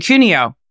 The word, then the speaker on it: Cuneo: Cuneo.